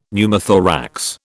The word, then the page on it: Pneumothorax: British English